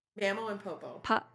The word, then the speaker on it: Mamo and Popo: And am I saying it right? Mamo and? – Mamo and Popo. Yeah.